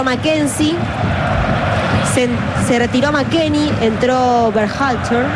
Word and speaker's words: Berhalter: Dígalo usted. – Berhalter. – ¿Y qué dije yo? – No, impecable.